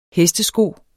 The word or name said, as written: hestesko